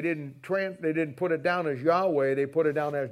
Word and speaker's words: Yahweh: The original name from God is that what we pronounce as Yahweh. And Yahweh it's hard to know exactly how to pronounce it because there's no consonants and there's no vowels. That's the closest we can get, Yahweh.